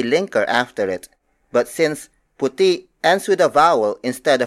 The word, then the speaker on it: puti: The opposite of itim is puti. Puti puti. Puti is spelled as P U T I.